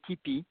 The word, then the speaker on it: TIPI: Is it Tippi? Am I pronouncing correctly? T-I-P-I? Tippi? – Yes, correct. It's a French research program, so in French we say Tippi, and here I guess you can say whatever is the most correct.